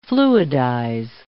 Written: fluidize

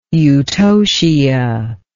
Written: eutocia

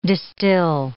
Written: distill

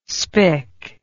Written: spik

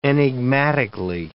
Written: enigmatically

